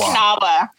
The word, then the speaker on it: Kanawa: I say Kanawa. – Well, and that's how it's spelled. It's less confusing to people who are reading it.